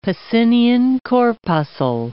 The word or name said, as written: Pacinian corpuscle